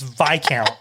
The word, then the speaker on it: viscount: I do know that it's Viscount. – It's not Viscount? – No. – There's an S. – I know, but the S is silent. – Well, I love the silent letter.